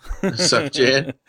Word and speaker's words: Sufjan: Sufjan.